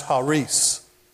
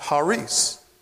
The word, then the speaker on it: Charis: The word grace is spelled C-H-A-R-I-S. And we name a lot of daughters. A lot of girls are named Charis. That's not how the word is pronounced. In the Greek, the word is pronounced Haris.